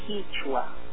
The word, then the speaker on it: Quechua: But now that in Ecuador, and I think I'm not sure in South America and the whole Andes, but we spell it Quechua. It's Quechua. Quechua. But it's pronounced Quechua.